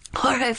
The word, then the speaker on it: Jorge: You can say George if you want. – It's not how it's pronounced. – Okay. – Jorge Fernandez died of a drug overdose October 7th after being taken to Glendale Adventist Medical Center.